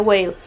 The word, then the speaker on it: Whale: Si no estoy mal, se pronuncia Whale. W-H-A-L.